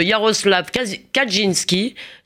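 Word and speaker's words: Jarosław Kaczyński: Jaroslav Kaczynski, c'est comme ça qu'on prononce Alain Finkielkraut oui.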